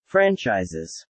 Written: franchises

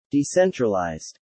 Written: decentralized

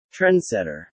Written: Trendsetter

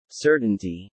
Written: certainty

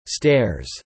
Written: stares

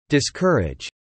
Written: discourage

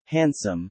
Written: handsome

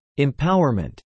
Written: empowerment

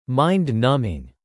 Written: mind-numbing

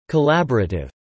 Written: collaborative

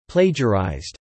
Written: plagiarized

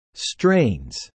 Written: strains

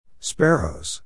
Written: sparrows

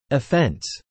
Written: offence

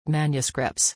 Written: manuscripts

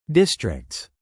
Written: districts

